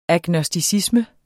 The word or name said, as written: agnosticisme